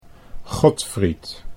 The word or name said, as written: Godfried